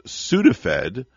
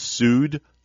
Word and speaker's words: Sudafed: The word is Sudafed, and then we'll separate it by syllables. It's Sudafed. – Ah, okay. – Sudafed. – Like suing a federal agent or something.